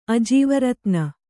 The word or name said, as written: ಅಜೀವರತ್ನ